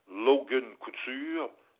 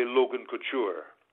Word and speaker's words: Couture: Lorsque le nom est à consonance française, il est prononcé de la façon dont il doit être prononcé en français. Je donne un exemple, je me vois mal annoncer un gars des Sharks de San Jose qui s'appelle Logan Couture, l'appeler Logan Couture.